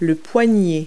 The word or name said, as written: wrist